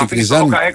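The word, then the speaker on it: Tiknizyan: Τικνιζιάν και τον Ρόκα. – Ο Τικνιζιάν με τον Ρόκα. Τικνιζάν, γιατί δεν προφέρεται, λέει, είναι περιμένει με το Αλφα. Τικνιζάν, λοιπόν, να το λέμε.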